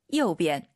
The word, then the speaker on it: 右边: 지금 일성 체크되어 있지만 일상생활에서는 경성으로도 많이 발음해요. – 对的. – 오른쪽, 요边보다는 右边. – 내려서 발음해도 괜찮습니다.